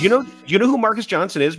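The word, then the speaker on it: Marques Johnson: Next, we have former Bucks wing Marquise Johnson. – Marcus Johnson. I didn't look at the pronunciation.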